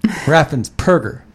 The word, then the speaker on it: Raffensperger: But Brad Raffensperger, spelled with a P, by the way. – Yeah. – So it's Raffens, R-A-F-F-E-N-S, and then there's a P as in Patrick. – Right. – Perger. Raffensperger. What kind of name is that? – Well, don't go making fun of people's names.